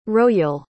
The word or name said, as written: roial